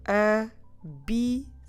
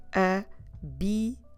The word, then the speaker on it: bicicleta: Feminine é uma palavra feminina, it's a feminine word, é uma palavra feminina, a bicicleta. That's right, four syllables. I will repeat slowly, devagar. Vamos repetir devagar. A bicicleta. A bicicleta.